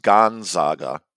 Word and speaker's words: Gonzaga: I think it's pronounced Gonzaga.